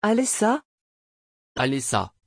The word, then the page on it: Alessa: French